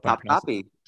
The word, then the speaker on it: Topkapi: I hope top copy. – Top copy. Okay. – Oh my God, you said it right.